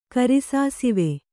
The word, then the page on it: ಕರಿಸಾಸಿವೆ: kari sasive